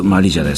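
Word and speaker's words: Marija: 私は J がつくマリジャです。これはですね、マリア、マリジャ、いろいろな読み方がありますけれども、マリアという名前はあちらでは、欧米では大変に多くてですね、スペルに J がつくマリアですよという意味なんですけれども、彼女の名前の発音はマリジャということになっているようです。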